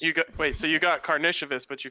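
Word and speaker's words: Artūras Karnišovas: The Denver Nuggets general manager, Arturas Karnishevas? – Hey.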